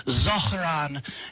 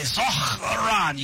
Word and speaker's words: Zohran: You see, you have to use a culturally sensitive pronunciation, you know, and so AOC, when she was speaking on behalf of Zohran Mamdani yesterday, she was like, it's Zohran. And so, you know, we got to say Zohran.